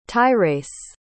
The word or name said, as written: tyrace